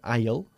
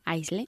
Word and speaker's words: aisle: Se pronuncia aisle, ¿ok? Aunque se escribe como se escriba. – Aisle.